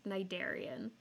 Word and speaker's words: cnidarian: It's spelled with a C. – I was going to say, when I read that word, that was not how I imagined it being pronounced. – It's spelled like cnidarian, but I'm pretty sure it's just cnidarian.